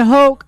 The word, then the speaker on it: Hoch: Bryan Hoch rhymes with Coke.